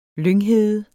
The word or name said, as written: lynghede